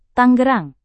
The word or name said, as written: tangerang